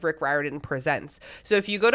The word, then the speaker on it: Riordan: He pronounces it Riordan. I don't really care that much. He doesn't. – I just did that. – You said Riordan. – Yeah, that's what I did. – Yeah. You did it right. – Oh, that's right. – Yeah. – Oh. – It rhymes with fire truck is like what they say to me.